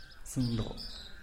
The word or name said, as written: zïndoo